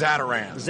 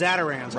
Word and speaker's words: Zatarain's: Tell me, how would you even pronounce the name of this? – It's a Zatarain's. – Zatarain's.